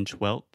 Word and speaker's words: twelfth: So there's an F in the word 12th. We write it T W E L F T H, I believe. – But the F, we don't pronounce that.